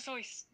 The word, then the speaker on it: Seuss: So the original um, it originally rhymed with voice, like Zeus voice, and thing, and he changed the pronunciation because everyone was saying Seuss, and he was like, yeah, sure.